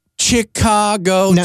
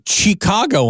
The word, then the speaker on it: Chicago: When you dropped a Chicago on us, I thought, well, that's interesting. A word that's C-H that starts with a sh.